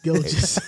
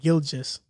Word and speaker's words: Gilgeous: You said Shea Wright, but you said Gilgeous. It's Gilgeous. – how do you say it? – Gilgeous.